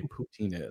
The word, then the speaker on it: poutine: poutine is and if I'm even saying it correctly? – Poutine.